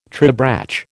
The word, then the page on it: tribrach: American English